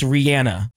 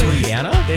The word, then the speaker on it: Rihanna: Were you a part of, like, where did you fall when everybody in radio was like, it's Rihanna and not Rihanna? – I always knew it was Rihanna. – It's not. It's Rihanna. Rihanna.